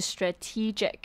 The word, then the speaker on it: strategic: 那我们首先要来看的第一个字呢，就是 strategic。 Strategic 呢，指的就是战略的，还有就是我们这个今天句子里面讲到的 strategic attention。那像它这边的发音比较特别，因为它 S T R A 的 A 呢，就发短音耳的音，然后重音是在 T E 的地方，所以是 strategic。